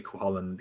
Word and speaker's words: Cú Chulainn: Cú Chulainn or Cú Chulainn? We say Cú Chulainn because, yeah.